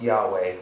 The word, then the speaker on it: Yahweh: Y-H-W-H. Sometimes pronounced Yahweh. You know, the Hebrew language doesn't have any vowels. So that's why it's written Y-H-W-H.